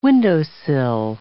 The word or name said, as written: windowsill